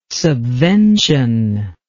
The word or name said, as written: subvention